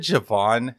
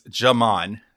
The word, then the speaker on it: Jamon: I said Javon several times on our last. – That's okay. – On our last pod episode, but it's Jamon, J-A-M-O-N, Dumas Johnson.